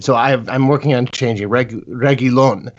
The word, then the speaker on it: Reguilón: You were making some interesting points, I think, before the game when the lineup came out, that it was a 3-5-2 or a 5-3-2 a lineup with Carvajal and Reguilón. Sorry, Reguilón. I've actually Reguilón. Found out recently that I was pronouncing that wrong. – Well, like a million people always thought there was an extra I in there after the L.